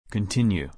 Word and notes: continue: long-u

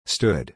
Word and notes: stood: /stúd/

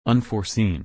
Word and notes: unforeseen: or

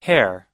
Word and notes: hare: er

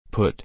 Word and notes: put: u

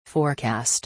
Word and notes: forecast: or

